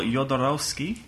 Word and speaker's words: Jodorowsky: it's by Alexandro Jodorowsky.